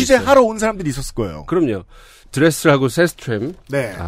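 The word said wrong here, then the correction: Sjöström: 아, 발음이 어떻게 됐죠? – 사라 셰스트램. 셰스트램. 네. 네 뭐 이 남녀 스웨덴 발음은 어렵죠.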